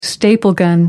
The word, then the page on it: staple gun: American English